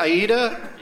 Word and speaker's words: Aida: Aida? – Yep.